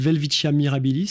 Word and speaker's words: Welwitschia mirabilis: Son petit nom, c'est Welwitschia mirabilis. – Oui, bravo pour la prononciation, elle n'est pas si facile. Welwitschia mirabilis, c'est une plante qui pousse seulement dans les déserts d'Angola et de Namibie, le désert du Namib, dans les déserts côtiers.